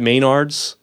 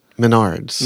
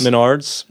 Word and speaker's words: Menards: Is it Maynard's? Menards. Menards.